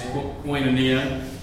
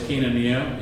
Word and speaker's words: koinonia: Well, the Greek word for communion, if you pronounce it the Erasmian way, it's koinonia. I think the more proper way is kinonia in Greek.